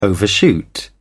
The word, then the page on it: overshoot: British English